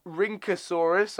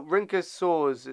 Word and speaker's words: Rhynchosaur: There were other things like the Rhynchosaurus, I think it's pronounced. – Rhynca. – R-Y-N-C-H-O. Oh, no, Rhynchosaurus. It's called Rhynchosaur. A Rhynchosaur. R-Y-N-C-H-O-S-A-U-R, okay?